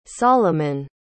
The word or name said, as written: salomon